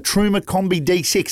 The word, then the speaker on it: Truma Combi: But first up, mate, a little tech tip, the Truma Combi D6. Have I said that right? – Yes, you have, mate.